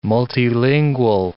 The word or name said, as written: multilingual